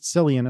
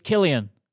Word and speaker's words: Cillian: I would pronounce it Cillian, and I would be wrong. – It's Killian. That's why. Okay. I did it again. I did this last time. It's Killian yep.